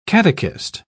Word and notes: catechist: British English

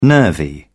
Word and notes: nervy: British English

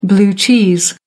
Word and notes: blue cheese: American English

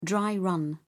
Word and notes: dry-run: British English

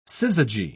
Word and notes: syzygy: British English

